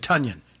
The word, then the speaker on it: Tonyan: That touchdown pass from Aaron Rodgers to Robert Tonyan, and by the way, that's how he's pronouncing his last name now. Tonyan. – Correct. – Tonyan. Did you guys see that? Correct. Yeah. – I did. – Think of Funyan, yeah. Bobby Tonyan, Robert Tonyan.